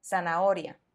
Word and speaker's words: zanahoria: Si te preguntas, ¿dónde está la H en esta palabra? Bueno, está en medio, pero no suena. Está justo después de sana y antes de oria. Quiero decir que la sílaba O está escrita con H. Sana oria, zanahoria. ¿Continuamos?